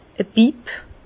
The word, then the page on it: e Bipp: Bas Rhin; Herrlisheim